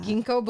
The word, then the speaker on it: ginkgo: Did you say gingico? – I don't know, how do you say that? – Ginkgo biloba. I'm sorry.